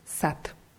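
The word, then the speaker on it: Sat (сад): Ja schreibt man S-A-D. D wird am Ende als T ausgesprochen. Sat.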